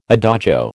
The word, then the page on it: adagio: American English